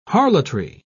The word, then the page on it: harlotry: British English